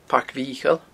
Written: Pairc Mhicheil

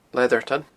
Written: Leatherton

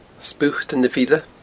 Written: Sputan a Bhile

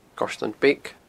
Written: Goirtean Beag

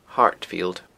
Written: Hartfield

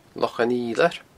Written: Loch an Fhidhleir

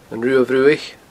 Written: Ruadh Bhruach

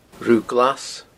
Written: Rubha Glas

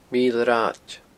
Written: Mile Rathaid

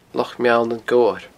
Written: Loch Meall nan Gobhar

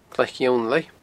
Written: Glaic Fhionnlaigh

